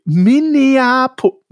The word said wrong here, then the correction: Minneapolis: Minneapolis heißt das, ne? Ja, Minneapolis. Minneapolis.